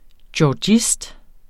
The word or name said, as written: georgist